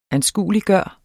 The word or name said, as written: ANSKUELIGGJORT